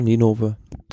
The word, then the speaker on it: Ninove: Omloop Het Nieuwsblad Gent nach Ninove. Oder so ähnlich ausgesprochen? – Ja, Ninove.